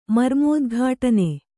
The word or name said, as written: ಮರ್ಮೋದ್ಘಾಟನೆ